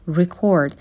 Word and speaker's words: record: ولی وقتی record به معنای ضبط کردن باشه استرس بخش دوم کلمه هستش. record.